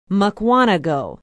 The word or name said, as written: mukwonago